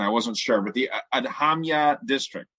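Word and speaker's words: Adhamiya: The handbill target audience is the parents of school-aged children in the Adhamya district. Am I pronouncing that right? You know, are you familiar with that district? Sure. – Okay. No, it looks good to me.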